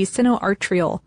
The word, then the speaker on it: sinoatrial: Those are the sinoatrial, I said that right, yes.